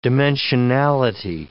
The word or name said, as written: dimensionality